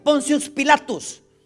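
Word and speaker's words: Pontius Pilatus: Primero por un gobernador de Judea llamado Poncio Pilatus en latín así se pronuncia, Poncius Pilatus, que era el gobernador de Judea impuesto por César, Tiberio.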